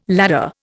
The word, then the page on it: ladder: Indian